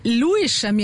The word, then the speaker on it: Lewisham: Wie spricht man es denn jetzt eigentlich richtig aus? – Ja, tatsächlich war die richtige Antwort gar nicht dabei. Es heißt nämlich Lewisham.